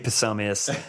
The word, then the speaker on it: Psomias: It's P-S-O-M-I-A-S. – Fun. That's fun. – You do pronounce the P. – Oh. – Very fun. – Yeah. Well, MV Psomius, congratulations.